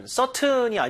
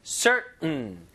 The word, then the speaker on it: certain: certain이 아니에요. certain. 이게 이제 성문 폐쇄음이라 부르는데 T 발음이 완전히 죽어서 목구멍에서 응 이런 소리 나요.